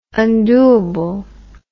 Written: undoable

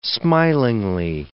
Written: smilingly

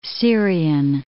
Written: Syrian

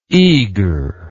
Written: eagre

